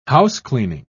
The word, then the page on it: housecleaning: British English